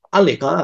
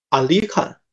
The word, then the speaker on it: Anglican: 安里干啊，在英文里是啊，我还不会念，就是 A N G L I C A N，安里干啊，我也不会念啊。这个英文的发音，它像什么呢？它像另外一个英文单词，叫安格鲁。